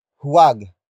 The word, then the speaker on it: huwag: Huwag ikagalit ang opinyon ng iba.